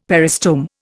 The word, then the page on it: peristome: Indian